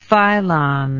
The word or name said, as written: phylon